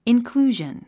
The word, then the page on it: inclusion: inklúːʒən; インクルージョン